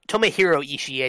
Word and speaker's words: Tomohiro Ishii: Tomohiro Ishii,